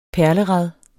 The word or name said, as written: perlerad